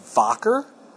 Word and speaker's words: Wacker: Just so you know, for future reference, they're a German company. It's pronounced Wacker. Don't say Wacker.